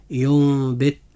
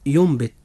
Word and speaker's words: ينبت: كلمة ينبت، هذه الكلمة حرف الإقلاب فيها حرف الباء، جاء بعد حرف النون الساكن، فهنا وجب الإقلاب، فتنطق هذه الكلمة هكذا ينبت، ولا تنطق ينبت لأن هذا يعد خطأ، إنما تنطق ينبت.